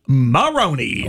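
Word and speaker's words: Moroney: Megan Maroney. – Oh, yeah. – Megan. – Rhymes with baloney. – Mm-hmm. It does. And pony.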